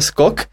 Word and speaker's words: Skok: Martin je totiž náš lingvista, poučil nás, že špatně vysko- vyslovujeme název restaurace Skok. Protože správně to skoč, nebo já nevím. – Ne, správně to má být Skok a jak Martin podotkl, tak...